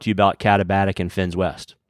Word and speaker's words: Finns West: Finns West. Yeah, that's right. Two N's there.